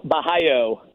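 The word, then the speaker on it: Borralho: How you say it? Barrio. Is that how the Brazilians say it? – Caio Bahio. – Bahio. Barrio. Well, all right. The R's, you know, they carry with the Brazilians.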